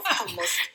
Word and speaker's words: hummus: So it's hummus, very easy. This lesson is finished now. Hummus, it's not hummus, it's not hummus, and it sounds a lot like the Homs city in Syria, but it's not the same pronunciation. Again, it's hummus, you have to double it.